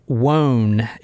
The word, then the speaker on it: Wone: Yeah, it's the one with Robert Wone. It's W-O-N-E. And from other newscast and stuff, I heard that's how they pronounce it.